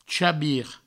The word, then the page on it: chabir: chabi; provençal